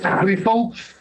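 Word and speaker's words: Griessel: In Afrikaans, it's Greasel. We don't expect people from other languages to say it in that way. Although the Dutch can. I think the Dutch are able to do that.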